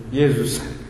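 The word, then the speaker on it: Jesús: come si pronuncia ancora? Jesus. – È Jesus. – È Jesus, ok.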